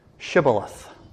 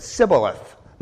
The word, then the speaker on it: Shibboleth: But many Israelites looked the same, so to distinguish between one from another, they asked them to pronounce a particular word, Shibboleth. That's how the Manassehites would say it. But when those from Ephraim tried to say it, they said Sibboleth, not Shibboleth, but Sibboleth. So they recognized them and would kill the soldiers from Ephraim.